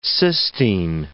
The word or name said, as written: Sistine